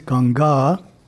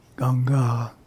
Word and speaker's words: Ganga: The word in Indian language is Ganga, long A on the second A, Ganga.